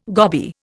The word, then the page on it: goby: Indian